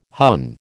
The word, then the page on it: Hun: American English